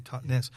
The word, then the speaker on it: Totnes: Yeah, it's the Totnes, as you say it properly, Totnes.